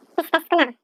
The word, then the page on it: pisistaaskwaan: Chisasibi